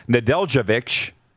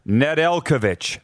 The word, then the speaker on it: Nedeljkovic: And in goal for Detroit, Alex Nedeljkovic, did I say that right? – Yeah. – Sounds like a tennis player. – It does. – Made 32 saves. Nedeljkovic, the goalie for Detroit.